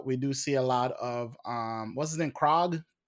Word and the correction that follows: Korg: So Korg.